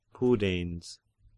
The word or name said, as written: Pudens